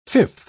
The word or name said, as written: fifth